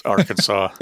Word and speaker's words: Arkansas: That's how you pronounce the state, right? Arkansas? – It's Arkansas. It's fine. I'm fine with, however anybody. Yeah. It is Arkansans, though, or so I've heard it pronounced.